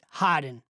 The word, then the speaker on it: Haydn: Pronounced it Haydn, as in hide and seek, like the composer, he added, though not as famous.